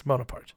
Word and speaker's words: Bonaparte: Yes, Bonaparte.